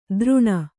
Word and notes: ತರುಣ: Kannada

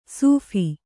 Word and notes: ಸೂಫಿ: Kannada; sūphi

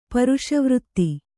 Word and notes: ಪುರುಷವ್ರತಿ: Kannada

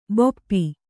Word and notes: ಭೂಪ: Kannada

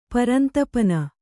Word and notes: ಪರಂತಪನ: kannada

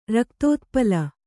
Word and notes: ರಕ್ತೋತ್ಪಲ: Kannada

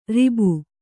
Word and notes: ಋಭು: Kannada

